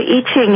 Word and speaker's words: I Ching: I said it the way it looks like. So it's called the I Ching, yeah? – Yeah.